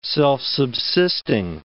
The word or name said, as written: self-subsisting